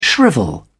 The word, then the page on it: shrivel: British English